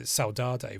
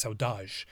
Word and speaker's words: saudade: Well, I as I read it as as I pronounced it when I read it, uh, saudade, though my Brazilian friend told me it's saudade, uh, is how it should be pronounced.